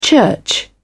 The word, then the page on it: church: British English